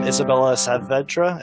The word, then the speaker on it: Isabella Savedra: Welcome, Isabella Savedra.